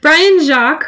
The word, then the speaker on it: Jacques: Brian Jacques, is that how you pronounce it? – Yes. – Okay. Brian Jacques, who wrote Redwall.